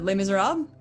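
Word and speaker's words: Les Misérables: Les Miserables? – Yeah, thank you.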